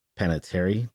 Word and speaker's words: Panettiere: Panettiere. Do you know who she is? She was in Heroes. – Wait, who was it? What was it? – Hayden Panettiere.